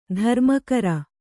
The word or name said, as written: ಧರ್ಮಗುರು